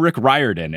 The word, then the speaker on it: Rick Riordan: So then I specifically looked for an interview where Rick said, "Hi, I'm Rick Riordan." And I said, "That's the authority I will trust, the man." So I know how to pronounce his name.